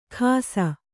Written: ಖಾಸ

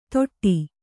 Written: ತೊಟ್ಟಿ

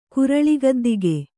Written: ಕುರಳಿಗದ್ದಿಗೆ

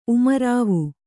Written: ಉಮರಾವ

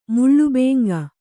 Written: ಮುಳ್ಳುಬೇಂಗ

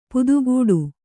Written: ಪದಗುಡು

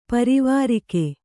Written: ಪಾರಾವಾರಗ

